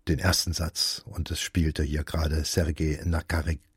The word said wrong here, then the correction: Nakarjakow: Na, jetzt wollen die Silben nicht so locker fließen. Also, Sergei Nakarjakow spielte die Trompete, das St. Paul Chamber Orchestra begleitete ihn dabei.